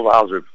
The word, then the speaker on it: Waasup: En we hebben aan de telefoon teammanager van Waasup 5. Zeg ik het goed zo? – Klopt, van Waasup.